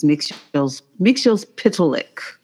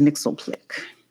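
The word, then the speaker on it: Mxyzptlk: You did hear four syllables because one of the pronunciations is Mixel's Pitalik, and another one is Mixelplick.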